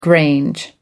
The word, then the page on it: grange: American English